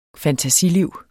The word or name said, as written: fantasiliv